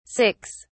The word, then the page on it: 6: British English